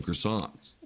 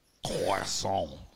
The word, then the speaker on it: croissant: That is pronounced croissant, not croissant. – Man, I'm killing it. I'm doing pretty good. – It's actually more with a W, but...